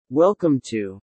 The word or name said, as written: Welcome to ...